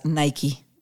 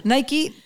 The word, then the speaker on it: Naiqui: Bienvenida a Mezclas Abruptas, Nike. – Hola. Hola. Nike, para que todos no crean que se escribe como la marca de tenis, es N-A-I-Q-U-I.